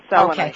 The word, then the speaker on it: selenite: This thing that we call selenite, which, am I pronouncing that right? – You are. Yeah, selenite.